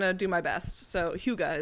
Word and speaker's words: hygge: I just showed Paige the word H-Y-G-G-E, and it is pronounced Hygge.